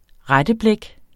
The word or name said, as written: retteblæk